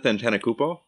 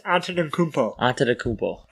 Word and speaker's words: Antetokounmpo: It's like Giannis Antetokounmpo. – Giannis Antetokounmpo.